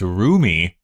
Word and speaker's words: Rumi: Some people think it's pronounced Rummy, but it's not. It's Rumi, because it has an umlaut.